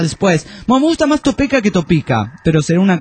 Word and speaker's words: Topeka: Y también solo corregir al pirata, es la ciudad de Topeka, así se escribe Topeka, pero se escribe así, pero se pronuncia Topeka. – Topeka.